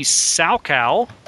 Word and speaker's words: Salkow: But eventually the director that did indeed film it, Sidney Salkow, S-A-L-K-O-W, I don't know any other way to pronounce that. – Salkow? – Was finally Salko? – I would say Salko. – I like Salko better than Salkow, I guess.